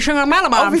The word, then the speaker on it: Shyamalan: Shyamalan. – Yeah. – I can never say his name. – Shyamalan.